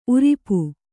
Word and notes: ಊರಪ್ಪ: kannada

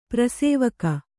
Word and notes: ಪಾರ್ಶ್ವಕ: pārśvaka

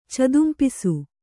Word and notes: ಚದುಂಪಿಸು: kannada

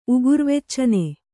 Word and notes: ಉಗುರ್ವೆಚ್ಚನೆ: Kannada

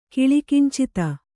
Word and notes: ಕಿಲಿಕಿಂಚಿತ: kannada

